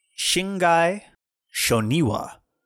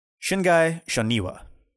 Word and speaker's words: Shingai Shoniwa: And it said, it is pronounced Shingai Shoniwa. The Shingai is typically pronounced with the emphasis on the first syllable, and Shoniwa is pronounced with the emphasis on the second syllable.